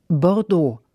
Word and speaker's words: Bordeaux: Bordeaux spricht man etwa so aus. Bordeaux.